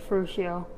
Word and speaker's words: Ferruccio: Is that how you say his name, you think? – Yeah, Ferruccio.